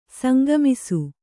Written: ಸಂಗಮಿಸು

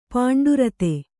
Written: ಪಾಂಡುರಿತ

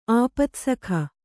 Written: ಆಪತ್ಸಖ

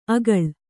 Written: ಆಗುಳ

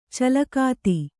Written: ಚಲಗತಿ

